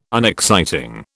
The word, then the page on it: unexciting: British English